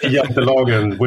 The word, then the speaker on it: Jantelagen: Is that how it's pronounced? Jantelag, not Jantelagen? – Yeah, we say Jantelagen, which is, you know, but it's not a law by any means.